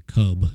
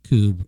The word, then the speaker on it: Kubb: And actually the word Coob, in some Scandinavian countries, they say cub because it's spelled K-U-B-B. That in the United States, we came up with Coob, kind of like rhymes with tube.